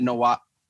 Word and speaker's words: Nwaba: David Nwaba.